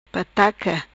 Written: Pataca

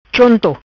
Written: Chonto

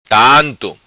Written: Tanto

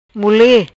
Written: Mule

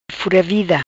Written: Fura Vida

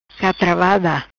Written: Catravada

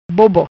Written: Bobo

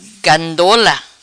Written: Gandola

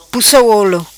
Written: Pussa Olo

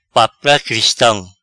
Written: Papia Cristam

